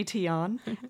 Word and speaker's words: Tian: You're working in a hotel and you fell in love with the lovely Tion. He's now your boyfriend. – You said his name perfect. – Tion.